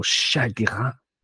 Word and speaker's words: chagrin: Joe Gomez, he had two shots last night, much to the chagrin of Jurgen Klopp, or chagrin, if you're going to use the French pronunciation.